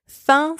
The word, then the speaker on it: faim: Fin, spelled F-A-I-M, means hunger. Fin, spelled F-I-N, means end. In both words, the letter F is pronounced F. The letters A-I-M together and the letters I-N together in this word are pronounced un. Un, which is a nasal vowel. Parfait. Third set of homophones. Foie, foie, and foie. Foie, spelled F-O-I, means faith.